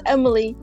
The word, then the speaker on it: Emily: So Toki is T-O-K-I. Meki is M-E-K-I. And then my name, which is Emily, E-M-I-L-Y.